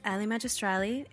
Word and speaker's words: Ellie Magistrali: My name is Ellie Magistrali. It rhymes.